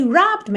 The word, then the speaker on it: robbed: O final dele é ED, mas o E não é pronunciado, certo? Então você não diz robbed e sim robbed. Robbed. Tá bem? Do B, você já vai pro D.